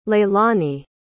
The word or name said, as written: leylani